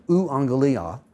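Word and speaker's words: euangelia: Now, in the Greek, there's no V, there's no, there's no V sound. There is a U sound. So, U angelia is good news, great message, awesome announcement.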